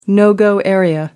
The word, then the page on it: no-go area: American English